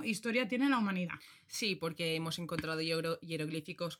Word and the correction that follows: jeroglíficos: con. ¿Se dice así? – jeroglíficos.